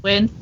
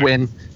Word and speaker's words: Nguyen: How do you pronounce his name? – Wynn. – Admiral Wynn. – It's just Wynn.